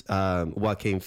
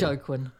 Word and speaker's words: Joaquin: So there's like hundreds and hundreds of, I guess, Joaquin Phoenix in that movie, Her. – Well pronounced. Everyone looks at that and goes, "How do you say that name?" – Yeah, I've been practicing. – Joaquin.